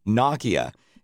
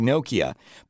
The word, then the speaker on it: Nokia: And if I'm not mistaken, it's supposed to be pronounced in English, Nokia. It sounds a little funny to us in North America. So a lot of people say Nokia, but they're not an Asian company, or else it would be Nokia, right? – Yeah, exactly.